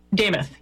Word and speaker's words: Demuth: Well, they debate, is it DeMuth? How does she pronounce her name? Lisa DeMuth? How does she say it? – Damuth. – Lisa okay. Damuth. So, all right.